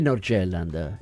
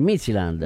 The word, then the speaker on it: Midtjylland: Non è il Nordjylland, è il Midtjylland.